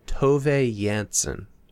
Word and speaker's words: Tove Jansson: And then we are to understand, based on the internet, that you pronounce the author's name as Tove Jansson.